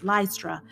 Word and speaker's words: Lystra: Here is the second opposition that they have encountered in Lystra. Is that how we're going to pronounce that? Yes, Lystra.